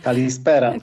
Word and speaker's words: Kalispera: Dobry wieczór. Kalispera, tak? – Kalispera. – Kalispera. Ja nie wiem, jaki jest akcent, muszą mnie Państwo poprawić. – Kalispera. – Tak, dobrze, Kalispera.